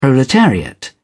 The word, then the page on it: proletariat: British English